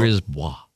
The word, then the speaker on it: Brisbois: No, I think it is B-R-I-S. – Okay. – B-O-I-S. Uh-huh. Brisbois.